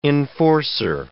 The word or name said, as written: enforcer